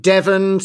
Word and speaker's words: Devon: It's me, down in Devon, still rhyming with heaven.